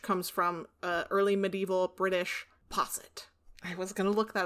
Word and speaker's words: posset: Okay, I think posset is right. – You were pretty close. – Well, that's how I said it, posset.